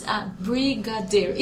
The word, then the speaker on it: Brigadeiro: It is Brigadeiro.